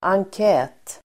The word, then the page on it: enkät: angk'ä:t